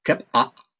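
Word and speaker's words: qep'a': And so tell us about the first K-L-I, or Kep'a, did I say that right? – Close enough, Kep'a, yes.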